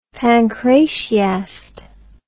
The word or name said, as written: pancratiast